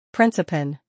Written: Principen

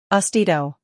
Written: Austedo

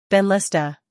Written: Benlysta

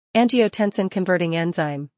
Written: Angiotensin converting enzyme

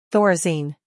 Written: Thorazine

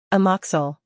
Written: Amoxil

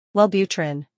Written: Wellbutrin

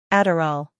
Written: Adderall

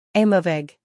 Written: Aimovig

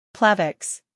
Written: Plavix

